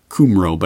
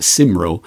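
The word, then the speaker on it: Cymru: And I saw other Corporate Report members like, it's spelled Cumro, but I have it on good authority. It's pronounced Simro, chiming in to say that that's a great idea.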